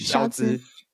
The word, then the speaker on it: Scholz: 肖兹。